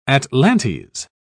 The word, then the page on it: atlantes: British English